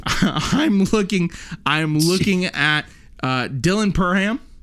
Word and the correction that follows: Parham: I think that's Perham, right? That's how you say it. – I would say Parham. – Is it Parham? Parham.